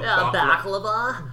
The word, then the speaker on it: baklava: How do you say it? Baklava. – Baklava. See, I was gonna say baklava. The white way.